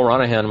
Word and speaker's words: Ronahan: Neil Ronahan was on Nintendo World Report's own. Neil Ronahan was on... you don't pronounce the G, right? It's a silent G is what I picked up. It's Han, yep.